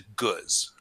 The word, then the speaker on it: gueuze: And so that is a guz, all right? It's a mouthful of a word. It's spelled variously G-E-U-Z-E or G-U-E-U-Z-E.